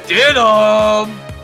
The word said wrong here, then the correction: Stidham: I believe it's pronounced Stidham.